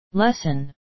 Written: lesson

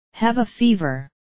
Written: have a fever

